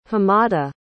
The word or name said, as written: hamada